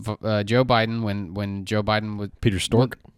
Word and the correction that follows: Strzok: Strzok, S T hmm. R O Z K. I've been saying it wrong this whole time.